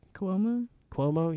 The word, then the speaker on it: Cuomo: Cuomo, yeah, I think so.